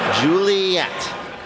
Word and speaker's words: Ghiuliyette: That's lovely, but Laureen has spelled it G-H-I-U-L-I-Y-E-T-T-E. Juliet. It's an incredibly elaborate way to spell it, and by elaborate, I mean dumb.